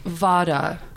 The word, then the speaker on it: Vata: Vata.